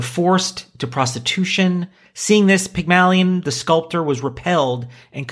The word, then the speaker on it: Pygmalion: But it starts with, in ancient Greek mythology, Pygmalion, perfect.